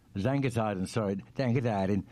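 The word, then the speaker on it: Zangersheide: Later, I worked at the famous Zangelshield. Is that how you pronounce that? – Zangersheiden.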